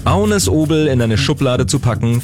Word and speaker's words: Agnes Obel: Ich spreche von Agnes Obel, wie man sie denn auch auf Deutsch aussprechen kann. Aber auf Dänisch sagt man zu ihrem Namen Agnes Obel.